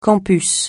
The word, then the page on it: campus: francais